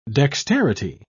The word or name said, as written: dexterity